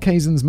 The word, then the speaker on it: Kazan: Kazan, there's a pronunciation guide. Elia Kazan, Kazan, like Steve Zahn.